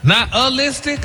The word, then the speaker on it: nihilistic: Stop putting the ooh in there. – Nihilistic? – There you go.